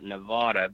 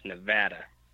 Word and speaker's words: Nevada: Well, a lot of people call it Nevada, but it's actually pronounced Nevada.